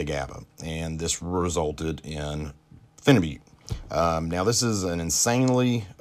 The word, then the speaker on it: phenibut: And the first one I'd like to talk about, it's people pronounce this a lot of different ways. I call it phenibute. Some people call it phenibut. There's different ways to pronounce it.